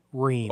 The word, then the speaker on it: Rehm: Todd Rehm. – Oh, good. Very good. Could you spell that last name for us? – R-E-H-M.